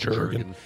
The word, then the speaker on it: Jurgen: It's a hard J. – Hard J. – So it's not Jurgen. – It's Jurgen.